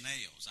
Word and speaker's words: Nells: Spell that for me. N-E-L-L-S. Sound like you were saying nails. Yeah. I understand. So it's spelled nails, but it's pronounced nails.